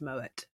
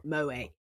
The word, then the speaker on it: Moët: And it's like, you pronounce it like this. It's Moët. It's not Moët. It's Moët.